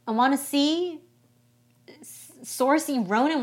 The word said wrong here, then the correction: Saoirse Ronan: whatever... Saoirse? – A lot of people gave you instructions on how to say this. – Saoirse Ronan.